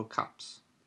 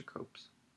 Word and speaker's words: copse: Is it pronounced copse or copes? I want to look this up. – What is it? – Copse. – Copse. – What is it? – It's like a little grove. Oh. Copse. – I've never heard it. – C-O-P-S-E.